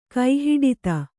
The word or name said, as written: ಕೈಹಿಡಿತೆ